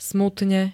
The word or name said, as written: smutne